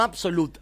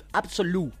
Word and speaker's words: Absolute: No Absolute, Absolute. Porque es francés.